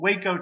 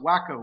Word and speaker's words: Waco: David Koresh of the Branch Davidian cult, Waco, Texas. You ought to pronounce it Waco with a guy like that running around, but it's pronounced Waco, actually.